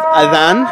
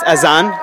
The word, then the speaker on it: Adhan: So, this is called Adhan, or as they pronounce it in many dialects, Azan, though the original pronunciation is Adhan.